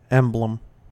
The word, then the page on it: emblem: American English